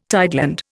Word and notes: tideland: Indian

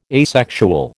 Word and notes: asexual: American English

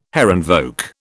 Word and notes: Herrenvolk: British English

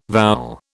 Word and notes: vowel: British English

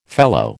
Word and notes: fellow: American English

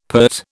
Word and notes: PERT: British English

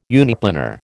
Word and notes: uniplanar: American English

